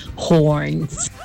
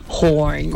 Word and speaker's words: horns: Nada más que tiene que abrir un poquito más la boca cuando diga horns.